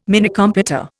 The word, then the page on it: Minicomputer: Indian